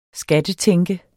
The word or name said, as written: skattetænke